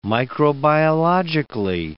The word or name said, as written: microbiologically